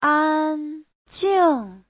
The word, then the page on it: 安静: an1jing4